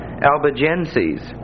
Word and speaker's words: Albigenses: The other two names are the Albigenses, Albigenses, and that's pronounced on the end like S-E-Z with a long E, sees, Albigenses. And the accent is on the middle syllable, Albigenses.